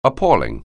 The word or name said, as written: appall